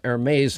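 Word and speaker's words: Hermès: I know what Hermes, how to pronounce it.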